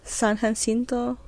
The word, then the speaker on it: San Jacinto: And she seduced the Mexican general Santa Anna at the Battle of San Jacinto, which, did I say that right? San Jacinto? Mm-hmm. San Jacinto, something like that.